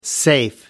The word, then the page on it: safe: American English